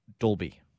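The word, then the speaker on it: Dalby: Hey, Nimsy, we also got a text from somebody else just correcting us on the pronunciation of Delby. It's actually Dolby.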